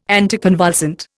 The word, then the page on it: anticonvulsant: Indian